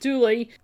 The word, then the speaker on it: Dula: Tom Dulla, actually, D-U-L-A. But I guess down wherever he's from, they pronounce it Dooley.